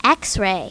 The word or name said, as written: xray